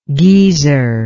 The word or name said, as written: Gezer